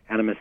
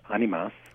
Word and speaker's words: Animas: Animas is a Spanish word, more properly pronounced animas. – Animas. – And it is Spanish for souls, plural. Uh-huh. And it's spelled A-N-I-M-A-S.